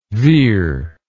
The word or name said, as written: vir